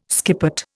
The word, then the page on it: skippet: Indian